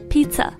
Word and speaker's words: Pizza: 总之记得那个玩意儿叫做 Pizza，以后不要再读错了。